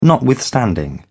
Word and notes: notwithstanding: British English

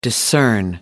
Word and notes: discern: American English

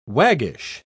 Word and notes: waggish: British English

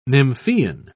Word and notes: nymphean: British English

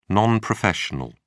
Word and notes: non-professional: British English

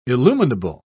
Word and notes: illuminable: British English

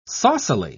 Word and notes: saucily: British English

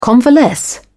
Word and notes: convalesce: British English

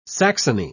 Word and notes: saxony: British English

